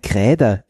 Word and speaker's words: Grader: nämlich ein Kräder ist es. Das war damals in Bayern und sie haben es sehr bayerisch ausgesprochen und ich habe das dann gegoogelt, Kräder, K-R-Ä-H-D-E-R oder so ähnlich, bis ich dann drauf gekommen bin, nee, das kommt von Great, also G-R-A-D-E-R, das halt Erdhobel.